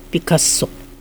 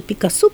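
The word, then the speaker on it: Picasso: Мы-то знали всегда Пикассо, потому что мы узнали из Франции. А во Франции кто бы ты ни был, у тебя будет ударение на последнем слоге.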